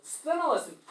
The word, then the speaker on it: Sthenelus: Sthenelus, perfect pronunciation. Very hard for us who speak American English to pronounce S-T-H, Sthenelus.